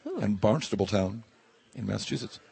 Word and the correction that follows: Barnstable: Paul Brennan, our producer, can pronounce it for us. – Barnstable. – Come on. There it is. That's it.